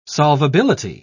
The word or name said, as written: solvability